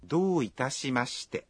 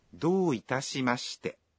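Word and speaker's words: Dou itashimashite (どういたしまして): Dou itashimashite? – Không có gì ạ. Khi phát âm, các bạn kéo dài nguyên âm o trong do thêm một nhịp.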